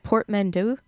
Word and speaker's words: portmanteau: I think in that chapter, how you started it off, I loved your first, you have to tell me how to pronounce the word. Is it portmanteau? – Yeah.